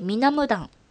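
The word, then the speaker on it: 美男堂: で、えー、韓国ドラマで、えー、ミナブダンの事件手帳というんですけど、あの美しい男のお堂と恋と、えー、書いて、みなむだんと読むんですけど、あのー、すごいね、イケメンが出てきます。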